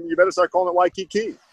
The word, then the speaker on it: Waikiki: It's Waikiki. It's pronounced Waikiki, man. You better start getting that right because the belt kind of lives here now.